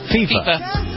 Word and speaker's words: FIFA: FIFA.